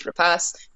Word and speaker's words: Noomi Rapace: starring uh, Noomi Rapace, um, I've been saying her name wrong for a very long time. – I've been calling her New Noomi Rapace. It's not. It's Rapace.